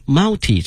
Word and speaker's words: multi: University students have also helped design a multi-function handcart, 多功能哈 multi 这个词我们讲个 multi 哈，都可以两个发音